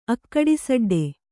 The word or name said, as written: ಅಕ್ಕಡಿಸಡ್ಡೆ